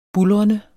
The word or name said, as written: buldrende